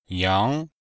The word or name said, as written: yang2